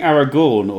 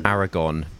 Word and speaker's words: Aragon: Wait, so it's saying Aragorn or Aragon? – Aragon. It doesn't have the R in it, so it's not spelt like the Lord of the Rings character. It's spelt like the element, right? – That's Aragon.